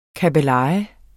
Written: kabbeleje

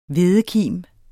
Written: hvedekim

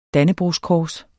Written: dannebrogskors

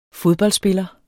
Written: fodboldspiller

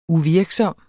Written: uvirksom

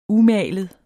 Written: UMALET

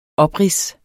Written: oprids

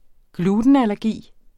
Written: glutenallergi

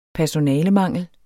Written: personalemangel

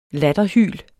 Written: latterhyl

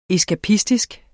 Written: eskapistisk

